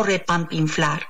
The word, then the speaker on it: repampinflar: Porque refanfinflar, por favor, a ver, ¿es refanfinflar o repampinflar? Si bien refanfinflar como repampinflar, es decir, tanto refanfinflar como repampinflar son válidos.